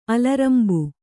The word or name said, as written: ಅಲರಂಬ